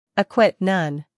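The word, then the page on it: acquit: uk əˈkwɪt; en-us əˈkwɪt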